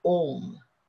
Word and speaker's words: Om: Okay, now when you see Om, it consists of three syllables, A, U and Ma. A, U and M, if we take the English listening, A and U are vowels and M is a consonant.